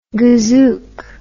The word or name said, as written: gazook